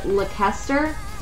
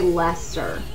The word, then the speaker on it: Lester: And if you read his name, it looks like you pronounce it Lekester. But they really just say Lester pronounce it.